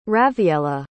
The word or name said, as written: rawiella